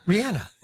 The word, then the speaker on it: Rihanna: I mean, Rihanna.